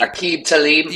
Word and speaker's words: Aqib Talib: But there are some people, Aqib Talib, I can't pronounce his name. Talib. – Aqib Talib. – Yes. Right.